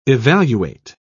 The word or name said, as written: evaluate